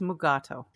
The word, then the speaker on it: Mugato: Mugatu on this planet and take him to another planet, I guess, where they're... – I like that you keep saying it wrong, too. It's Mugato.